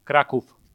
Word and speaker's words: Krakow: How do you say Krakow? – Krakow. – Krakow? – Yep. – With an F? – Yep.